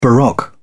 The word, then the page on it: baroque: British English